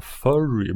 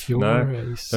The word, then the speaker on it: Furey: Uh, the Furies, Furies ska ju tydligen uttalas. – Eh, mm. Det var deras efternamn. Det stavas F-U-R-E-Y. Så jag tänkte heta Furry, men det blir konstigt. Furries. – Furies. Nej. uh, ChatGPT, som ju aldrig har fel, sa i alla fall att det ska uttalas som Furies, alltså ungefär som Fugees, Fujis, eller Furies då. Fugees mm. fast med, med R.